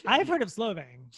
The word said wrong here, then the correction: Solvang: Solvang, yeah. – I'm pronouncing that way wrong. – I was like, Slovenia? I'm butchering that completely.